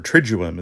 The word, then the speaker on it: Triduum: Triduum is how you say it.